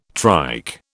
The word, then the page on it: trike: British English